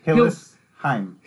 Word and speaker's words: Hillsheim: Because in my mind I said, it sounds like Hillsong. Hillsheim.